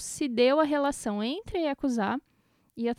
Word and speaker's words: Yakuza: Que, aliás, essa é a pronúncia, não é Yakuza, como todo mundo pensa. – É que nem a gente sempre conversa, o japonês não tem sílaba tônica. Então, quando você vai ler, você lê do jeito que a sílaba é lida. Então, é Yakuza.